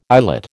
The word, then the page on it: islet: American English